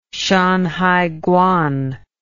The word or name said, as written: shanhaiguan